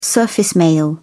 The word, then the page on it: surface mail: British English